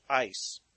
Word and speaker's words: eis: It is the Greek word eis, E-I-S, is how it's spelled.